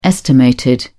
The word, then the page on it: estimated: British English